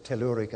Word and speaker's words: telluric: It's a she earth, a feminine earth, and it's telluric, as far as I know, isn't it? I pronounce it telluric ash, ash from the earth.